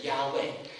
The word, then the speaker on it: Yahweh: 那么耶和华，那么以色列人他们对神非常的尊重，所以他们碰到神的这个名字，也许叫发音应该念成 Yahweh，但是他们不敢这样念，他们只敢说是 Adonai，意思就是说我的神，或者说是我的主。